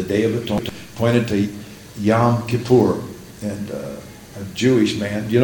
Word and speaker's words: Yom Kippur: We say Yom Kippur. He says it's really Yom Kippur.